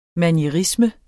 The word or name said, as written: manierisme